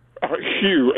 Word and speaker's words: Hugh: Hugh, H-U-G-H.